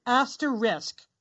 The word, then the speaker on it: asterisk: So it's asterisk, R-I-S-K, and a lot of people say ricks, like reversing the K and the S.